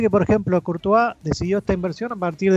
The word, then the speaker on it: Courtois: ¿Cómo se, pronuncia, Dani, el apellido? Ayúdame. Courtois.